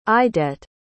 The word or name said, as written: idette